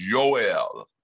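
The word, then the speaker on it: Yoel (יואל): And in Hebrew, the word is, his name is pronounced Yoel.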